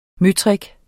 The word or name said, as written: møtrik